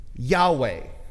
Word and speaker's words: Yahweh: Yahweh is how it's pronounced.